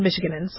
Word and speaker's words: Michiganans: And so what's very, very frustrating for Michiganans, and if you pronounce it, people live in Michigan, Michiganans, okay? See, Nevadans is easy.